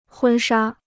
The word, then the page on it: 婚纱: hūn shā